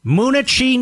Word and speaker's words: Moonachie: Jorge, Moonachie, New Jersey.